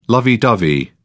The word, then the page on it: lovey dovey: British English